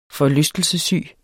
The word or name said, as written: forlystelsessyg